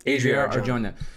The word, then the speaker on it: Adria Arjona: And now we're adding Adriana Arjona. Is that how you say your name? I'm sorry. – Adria Arjona.